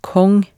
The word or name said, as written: Kong